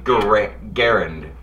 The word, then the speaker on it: Garand: Yeah, I said Grand. And I'm like, it's pronounced Garand.